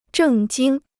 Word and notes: 正经: zhèng jīng

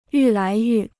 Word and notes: 愈来愈: yù lái yù

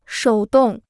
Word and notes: 手动: shǒu dòng

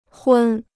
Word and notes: 昏: hūn

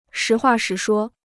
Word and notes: 实话实说: shí huà shí shuō

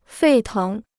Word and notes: 沸腾: fèi téng

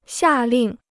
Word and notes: 下令: xià lìng

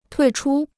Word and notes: 退出: tuì chū

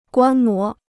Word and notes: 观摩: guān mó

